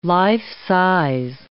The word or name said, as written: life-size